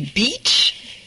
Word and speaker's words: beach: Donc, la plage se dit « beach ». C'est un « i » long, qui est long.